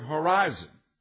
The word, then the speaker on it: horizo: The word there, determined, is a Greek word from which we would get the word horizon. And you pronounce the word horizo, which is very close to our understanding of the word horizon. And when you look out across the horizon,